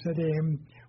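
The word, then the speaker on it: Siddim: And all these were joined together in the vale of, I think it's properly pronounced, Sidim, we would say Sidon, which is the Salt Seers in the area of Sodom and Gomorrah.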